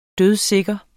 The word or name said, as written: dødsikker